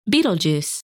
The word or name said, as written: Betelgeuse